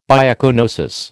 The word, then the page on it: biocoenosis: American English